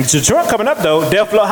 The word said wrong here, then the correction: Dej Loaf: Dej Loaf. – Dej Loaf.